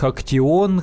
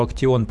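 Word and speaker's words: Actyon: что-то там продается у них Актион, хотя пишется слово как экшен, но называют они его Актион,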